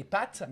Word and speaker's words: pâte: Because it's like pâte in French, tu manges des pâtes. – Yeah. – But because the accent is on the A, you should pronounce it pâte.